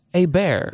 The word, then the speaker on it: Hebert: It's pronounced Hebert. – Not Hebert. – But it's spelled Hebert.